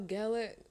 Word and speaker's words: Gellar: Do you remember when, like, Sarah Michelle Gellar, what am I saying? – Yep, you're saying it right.